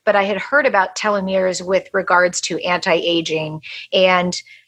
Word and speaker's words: telomeres: Telomeres, is that how you say it? – Yep, telomeres.